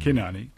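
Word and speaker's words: Cynane: Actually, I think in Greek it would be Kinani. – Kinani, that's right. – Have in mind for her daughter, Adea. – Well, Kinani and Adea are a fascinating pair.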